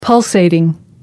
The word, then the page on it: pulsating: American English